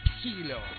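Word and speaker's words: Psilo: Esto es Silo. Silo. Va con P antes, por las dudas. Silo. Pero se escribe con P.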